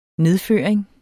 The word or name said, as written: nedføring